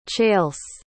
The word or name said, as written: chaylse